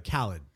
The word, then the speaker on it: Khaled: It's pronounced Khaled. DJ Khaled. – DJ Khaled, yeah.